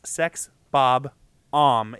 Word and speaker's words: Sex Bob-omb: And I tried to emphasize the syllables there. It's Sex Bob-omb, if you're not familiar.